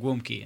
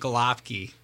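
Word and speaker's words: gołąbki: The Polish term of it is actually called gwumki, and it's a stuffed cabbage that we do. – Gwumki? – Gwumki, yes. – Okay. – But if you see it as it's spelled, it looks like galopki. – Okay. – And it's one of those things that's it's very misrepresentation of how it's pronounced.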